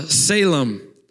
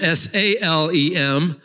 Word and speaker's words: Salem: The, the second word is the word uh, Salem, um, S E L E M. There's a city named after this. We spell that S A L E M, city of peace, Salem.